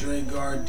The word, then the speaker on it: Dregarde: Dregarde, D R E G A R D E.